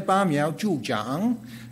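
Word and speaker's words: Ba miao zhu zhang (拔苗助长): It's ba miao zhu zhuang. My Chinese is not so good there. Ba miao zhu zhang is pronounced.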